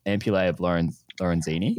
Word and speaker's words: ampullae of Lorenzini: Is that part of the ampullae of Lorenzini? – Yeah.